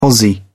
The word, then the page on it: aussie: British English